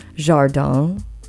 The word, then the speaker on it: jardin: Oh, I'm so excited because I did look up the pronunciation of this. Jardin, which means garden in French. And I took many languages over the course of my youth and young adult years. French was not one of them. And I feel like in past shows, I've just said jardin, which would be like the Spanish pronunciation of a French word.